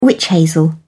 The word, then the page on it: wych hazel: British English